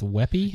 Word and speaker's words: WebP: They say it's pronounced Weppy.